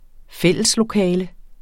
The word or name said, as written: fælleslokale